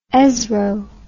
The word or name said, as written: ESRO